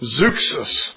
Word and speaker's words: Zeuxis: And this medical school had been founded by Zeus, spelled like Zeus, but instead of the S at the end, put X-I-S, Zeuxis.